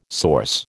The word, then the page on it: source: American English